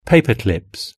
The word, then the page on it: paperclips: British English